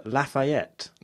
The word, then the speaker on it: Lafayette: And the L stands for Lafayette. – Mm. – I think that's how you say it. – It is.